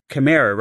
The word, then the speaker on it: chimera: You're kind of a chimera, right? Is that how you pronounce that word? – Mm-hmm.